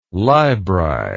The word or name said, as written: libri